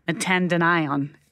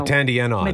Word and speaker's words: metandienone: A positive test result for a banned substance known as metandienone. Did I say that right? – No. metandienone. Metandienone.